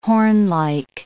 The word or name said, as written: hornlike